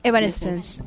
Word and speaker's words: Evanescence: Bueno, entonces estamos esperando a Paramore y Evanescence, ¿cierto? – Sí. – ¿Se pronuncia así? – Evanescence.